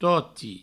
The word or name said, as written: toti